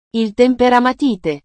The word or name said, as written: il temperamatite